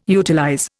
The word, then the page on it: utilize: Indian